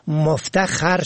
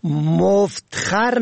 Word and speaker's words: مفتخر: به گونه مثال در جمله فلانی به دریافت سند فراغت و یا اخذ تحسین نامه مفتخر شد. ببینید این کلمه در نوشتار بدون ه دو چشمه مفت جمع خر نوشته می شود، اما در تلفظ آن را مفت خر نه بلکه مفتخر می خوانند.